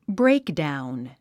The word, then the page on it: breakdown: bréikdàun; ブレイクダウン